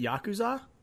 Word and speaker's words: Yakuza: How do you say Yakuza? Is it Yakuza? It's Yakuza. Yakuza, whatever. The Yakuza. Yakuza. – Yakuza. That's how the that's how the white people from Iowa say it.